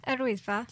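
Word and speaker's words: Yr Wyddfa: I'm not pronouncing the Welsh name again, how's it? – Erwydfa.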